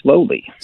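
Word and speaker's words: Sloly: Is it Slowly? Is that how you pronounce this guy's name? – I believe, very ironically, his name is Slowly.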